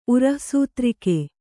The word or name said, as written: ಉರಃಸೂತ್ರಿಕೆ